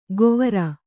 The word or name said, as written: ಗೊವರ